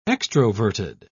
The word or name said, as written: extroverted